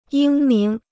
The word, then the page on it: 英明: yīng míng